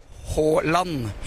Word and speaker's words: Haaland: I would like to know about Holland, because in Brazil we say Holland. – Yeah. – In Norway you guys say Holland, right? – Holland.